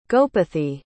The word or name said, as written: gopathi